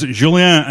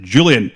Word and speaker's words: Julien: That is Julien and not the anglicised Julian, as in Julian Dix.